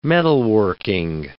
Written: metalworking

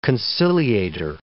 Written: conciliator

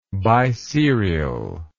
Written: biserial

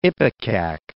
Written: ipecac

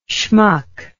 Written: shmuck

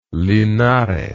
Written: linares

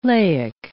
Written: laic

